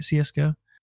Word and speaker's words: CSGO: Did I say that right, CSGO? – You did.